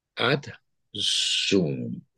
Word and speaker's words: adsum: The one series that truly, truly speaks to me is a series called Ad Zoom. So it's spelled ADSUM. It's a Latin word, and it stands for I'm here. I'm present, but it's in Latin.